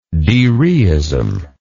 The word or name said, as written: dereism